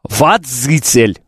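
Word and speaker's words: Вадзіцель: Вадзицель. Вот так вот, водитель. Вот. Это не коверкать, а правильно говорить.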